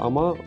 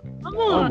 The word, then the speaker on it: ama: ама. Ну, «А Не-не-не-не-не-не, смотри. Ты ударение ставишь на вторую а. Я получается ама. А надо ама.